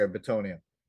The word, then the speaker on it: Bitonio: Bitonio. Does anybody know how to say that? Am I saying that right? – Yeah, you're saying it correct. – Okay, Bitonio it, he's better than Jake Matthews.